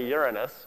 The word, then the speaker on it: Uranus: Notice very carefully how an astronomer pronounces the name of that third large planet. And we've heard enough of those locker room jokes about, they found rings around Uranus and so on, so we say Uranus.